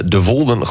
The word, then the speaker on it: De Wolden: De Wolden gewoon toegankelijk, zeg ik dat goed? – Ja, dat zeg je helemaal goed.